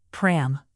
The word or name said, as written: pram